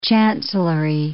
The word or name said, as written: chancellery